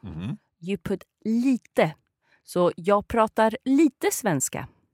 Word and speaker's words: lite: Lite. – Lite. Okay, so, jag pratar lite svenska. – Perfect. And it's important for English speakers to remember that we always pronounce the E's on the end in Swedish. Mm-hmm. So, lite, spell L-I-T-E.